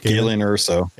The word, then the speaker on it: Galen Erso: It's your life or, in chat, the Mothman's Ranger, Galen Erso. Galen? I forget the pronunciation. – Galen Erso. – Yeah.